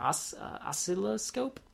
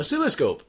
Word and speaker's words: oscilloscope: It was, I believe it's like an oscilloscope. I can't say that word. How do you say that word? – Oscilloscope. – Oscilloscope, thank you.